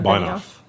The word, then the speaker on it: Benioff: So Mark Benioff, is that how you pronounce that? – Yeah, Benioff.